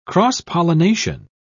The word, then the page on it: cross-pollination: British English